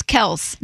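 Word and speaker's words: Kelce: He said that it's one syllable. It's Kels, not.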